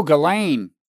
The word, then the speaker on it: Ghislaine: And we had uh, we have we have Jelaine Maxwell. Oh, Ghislaine. Uh, Ghislaine, yeah, so she she Sorry.